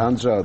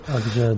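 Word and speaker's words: Ağca: знаменательна и многолетняя, и раскаяние этого волка Анджи, да, правильно я произношу? – Да, серые волки. – Его имя Анджа, да. – Анджа, да.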